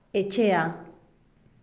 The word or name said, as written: etxea